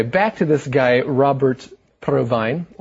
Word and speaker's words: Provine: Well, it's Robert Provine. – Well, see, that's the thing. I thought it was Provine, So, I'm not sure. – If it's provine or provine.